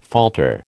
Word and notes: falter: American English

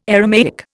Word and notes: Aramaic: Indian

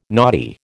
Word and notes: naughty: American English

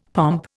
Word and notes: pomp: Indian

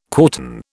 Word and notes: quartan: British English

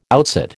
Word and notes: outsit: American English

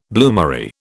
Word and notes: bloomery: British English

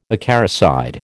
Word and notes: acaricide: American English

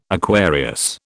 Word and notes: Aquarius: British English

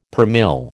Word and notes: per mille: American English

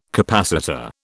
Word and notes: capacitor: British English

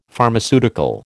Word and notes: Pharmaceutical: American English

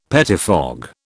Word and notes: pettifog: British English

